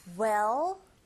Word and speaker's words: well: 근데 well 발음하실 때 이 L 사운드가 이게 다크 L 사운드라고 해서 여러분들이 발음하실 때 그냥 well이 아니라 혀끝이 이렇게 올라가면서 이 앞니 뒤에 붙여야 돼요. 그래서 정확한 발음이 well, well. 제 입을 보시면 well 하가 네, 혀가 윗니 뒤에 붙게 돼요.